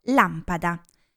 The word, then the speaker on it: lampada: Mm, ecco, lampada, che quasi tutti i miei studenti pronunciano lampada. Se scrivo la parola lampada in questo programma, mi dà la pronuncia corretta con l'accento, l'enfasi sulla prima A. Lampada.